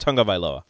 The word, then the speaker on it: Tagovailoa: However it looks, I don't even know how it looks, but it's pronounced Tagovailoa.